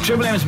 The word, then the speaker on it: Triple M: G'day, it's Bernie, Bluey and Jars from the Trillium Rush Hour. – Trillium. – Did I say that wrong? – Yeah. – Okay, I'll do it again. Triple M's making your workday 50,000 times better.